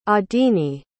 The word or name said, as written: adine